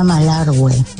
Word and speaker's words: Malargüe: Se pronuncia Malargüe. Jota.